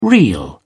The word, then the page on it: real: British English